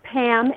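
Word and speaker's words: Pamm: So my name is Pam, and it's spelled with two M's.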